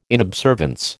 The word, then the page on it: inobservance: American English